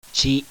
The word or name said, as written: TI